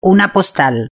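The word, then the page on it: una postal: Spanish (Spain)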